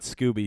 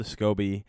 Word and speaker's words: SCOBY: And it's spelled S-C-O-B-Y. Yeah, SCOBY. Not Scooby, SCOBY.